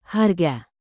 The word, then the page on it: harga: indonesian